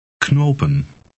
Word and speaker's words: knopen: In Dutch, the K is pronounced. Read 25 aloud. – Knopen.